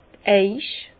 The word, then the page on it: d' Eich: Alsatian; Bas-Rhin; Herrlisheim